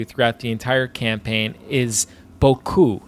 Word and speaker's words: BoKu: There is a very clear emphasis, a stressed syllable on the ku of BoKu. So it's not BoKu, it's BoKu.